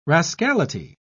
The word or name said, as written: rascality